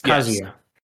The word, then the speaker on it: Kazuya: I don't know how you pronounce his name. – Kazuya. – Yes. Kazuya. Yes, Kazuya.